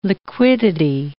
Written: liquidity

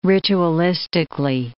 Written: ritualistically

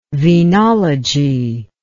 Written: venology

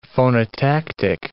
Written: phonotactic

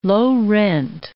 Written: low-rent

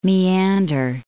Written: Maeander

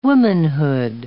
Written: womanhood

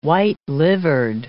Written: white-livered

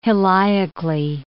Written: heliacally

